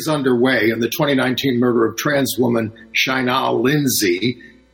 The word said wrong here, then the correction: Chanel: Chanel. The family pronounces it Chanel.